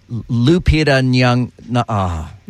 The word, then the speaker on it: Lupita Nyong'o: Lupita Nyong'o. I think I had the words backwards. Lupita Nyong'o. – Yeah, you said it right.